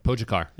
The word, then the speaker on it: Pogačar: We just see, you know, we just see the Pogačar, and I nailed the pronunciation of his last name.